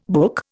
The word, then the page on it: book: Indian